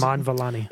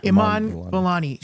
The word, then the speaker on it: Iman Vellani: What is Iman Vellani? – Iman Vellani.